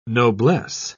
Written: noblesse

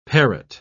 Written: parrot

